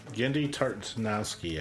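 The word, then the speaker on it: Genndy Tartakovsky: Gendy Tartanowski, I believe it's pronounced.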